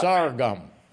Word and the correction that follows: sorghum: Sour gum. – Sorghum? – Sorghum.